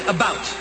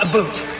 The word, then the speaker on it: about: I Sally speak English Susie and French, Canada, not American, and I pronounce it about, not a boot.